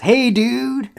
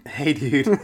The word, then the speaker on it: Hajduk: And also, I think it's pronounced "Hey dude." – Hey dude. Yeah, Frankie Hey dude.